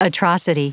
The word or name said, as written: atrocity